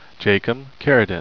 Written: carridin

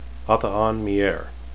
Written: athaan

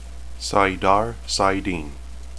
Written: saidar

